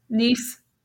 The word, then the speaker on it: Nice: Nice. Cannes, Nice. Those are the pronunciation.